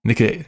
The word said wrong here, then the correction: Nicieza: oh gosh, Nicieza, directed by David Leitch.